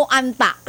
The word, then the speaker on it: hantavirus: Hanta o anta? – Ika bang panganan eh? – Oo nga, silent H.